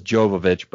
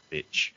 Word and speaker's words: Jovovich: Everyone says Jovovich, but it's actually pronounced Jovovich. Yeah. – The J is pronounced with a Y.